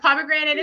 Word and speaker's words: Pomegranate: double check the spelling of pomegranate. It's tricky. It's a mouthful. We're sorry. – It is hard to spell. I always spell it wrong. – Natalie, our receptionist, says it's Pomegranate, like granny, 8.